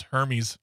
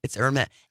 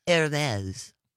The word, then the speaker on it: Hermès: I thought it was Hermes. – No, it's Hermès. Hermès.